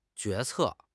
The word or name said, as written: 决策